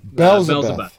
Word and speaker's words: Belzebuth: How do you pronounce that? – Belzebuth. – Okay, Belzebuth.